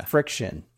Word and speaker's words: FriXion: This is is this FriXion? Yeah. Yeah. FriXion. erasable. spelled stupidly.